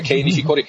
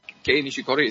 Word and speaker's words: Kei Nishikori: Nee, Kei Nishikori. – Also er, Gei Nishikori ist,